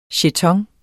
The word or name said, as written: Jetoner